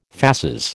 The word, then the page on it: fasces: American English